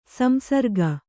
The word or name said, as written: ಸಂಸಾರಿಕ